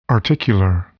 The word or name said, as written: articular